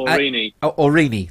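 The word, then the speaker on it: Aurigny: It's spelled Orini. – Thank you. Legendary. So Orini is to operate flights out of Guernsey.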